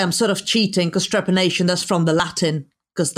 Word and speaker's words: trepanation: I'm gonna pronounce this right. Trepanation? – Trepanation or trepanning? Trepanation.